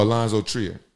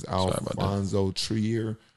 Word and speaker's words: Alonzo Trier: Oh, Alonzo Trier. Trier. – Yeah. – We gotta get you like better with pronunciations. We're gonna like pronunciation class. But I think it's Alonzo Trier.